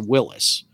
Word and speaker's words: Willys: It matters how you pronounce it. It is spelled Willys through the history of the whole company, but the family name is pronounced Willis, John North Willis.